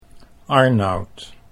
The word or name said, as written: Arnoud